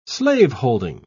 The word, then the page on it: slaveholding: British English